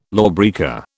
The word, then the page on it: lawbreaker: British English